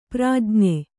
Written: ಪ್ರಜ್ಞ

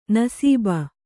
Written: ನಸೀಬು

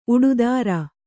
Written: ಉಡುದಾರ

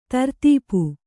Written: ದಾರಿದಪ್ಪು

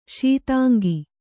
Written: ಶೀತಾಂಗಿ

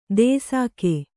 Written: ದೇಸಾಕೆ

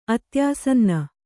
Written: ಅತ್ಯಾಸನ್ನ

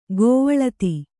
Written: ಗೋವಳಿತಿ

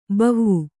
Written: ಭವ

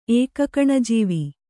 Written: ಏಕಕಣಜೀವಿ

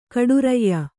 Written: ಕಡುರಯ್ಯ

